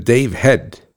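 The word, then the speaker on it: Dave Hedt: So we're going to have Mr. Dave Head. His name is pronounced H-E-A-D, like your head, but it's spelled H-E-D-T.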